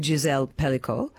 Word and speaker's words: Gisèle Pelicot: This as well, I'm just wanting to know, you actually dedicated it to the one, well, to Gisèle Pelicot.